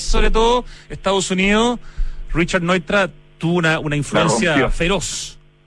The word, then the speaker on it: Neutra: Leía que era un gran admirador de Richard Neutra, no sé si está bien pronunciado Neutra, pero... – Neutra.